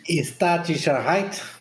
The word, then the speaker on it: Staatssicherheit: Como é que é a pronúncia do nome da Stasi? Staatssicherheit. É, assim. Meu alemão é perfeito.